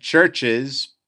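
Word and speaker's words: Chvrches: The band is Churches, you know, with the V where the U is. That band, Churches, is how you pronounce it.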